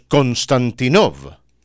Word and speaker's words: Konstantinov: Si usted tiene el apellido de Konstantinov, en español lo pronunciamos Konstantinov.